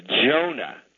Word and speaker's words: Jonah: My name is Jonah, J-O-N-A-H, as in Jonah in the whale.